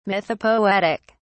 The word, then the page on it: mythopoetic: British English